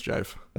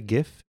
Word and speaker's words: GIF: I actually watched a whole video about how it should be pronounced, and it should be pronounced jife. – Okay. People say gif, and this is the first time I've heard jife.